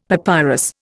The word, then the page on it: papyrus: Indian